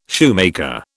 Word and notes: shoemaker: British English

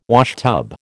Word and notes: washtub: American English

